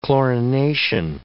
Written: chlorination

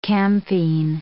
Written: camphene

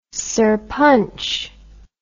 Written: sarpanch

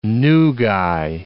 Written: nugae

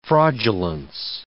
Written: fraudulence

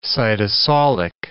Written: cytosolic

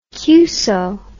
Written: cuso

